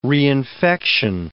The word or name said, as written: reinfection